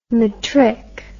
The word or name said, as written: matric